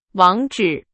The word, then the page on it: 网址: Chinese